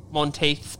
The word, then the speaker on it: Monteith's: Monteith's beer is the little shovel on the neck.